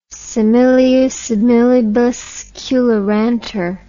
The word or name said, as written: similia similibus curantur